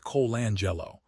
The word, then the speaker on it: Colangelo: In the NBA expansion race, groups led by names like Magic Johnson, Bill Foley, and Jerry Colangelo, pronounced Colangelo, are staring down entry costs between $8 and $10 billion.